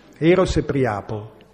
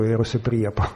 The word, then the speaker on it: Eros e Priapo: Allora legga lì, Eros e Priapo, per pronunciare da classicista, se no si è sempre detto Eros e Priapo.